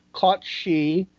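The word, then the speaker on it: Cat Sìth: So I was looking up the Cat Sith, and it mentioned the both versions are pronounced Caught She, and translates into English as fairy cat.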